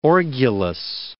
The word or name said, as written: orgulous